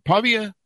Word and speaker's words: Pavia: She has a PhD in economics from Santa Ana School for the Advanced Studies and MA and BA in philosophy from Pavia University. Did I pronounce that right, Clara? – You did.